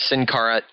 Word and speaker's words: Sin Cara: We incorrectly pronounced his name last week as Sin Cara. We weren't sure. It was just one of those things where you see that he was going to be there, and you kind of try to pronounce it the best way that you can. However, though, obviously corrected this week as Sin Cara.